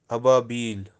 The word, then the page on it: ababeel: Kashmiri